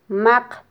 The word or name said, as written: maq